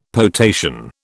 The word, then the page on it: potation: British English